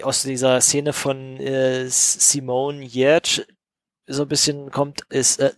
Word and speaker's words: Giertz: Auf Schwedisch spricht sich das wohl Yerch aus.